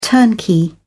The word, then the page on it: turnkey: British English